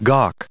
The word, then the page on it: gawk: American English